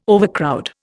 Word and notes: overcrowd: Indian

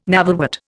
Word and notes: navelwort: Indian

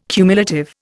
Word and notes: cumulative: Indian

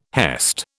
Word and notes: hest: British English

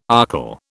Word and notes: archil: British English

